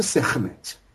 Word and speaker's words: Sekhmet: A pronúncia correta é Sekhmet.